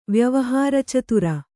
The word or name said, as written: ವ್ಯವಹಾರಚತುರೆ